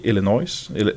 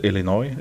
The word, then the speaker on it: Illinois: För att eh, det är så att det är ett företag eh, i Illinois, Illinois uttalas det väl, i eh, USA.